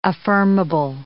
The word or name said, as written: affirmable